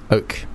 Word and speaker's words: Oak: Oak. Yeah, like the tree.